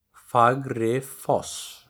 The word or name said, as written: Fagrifoss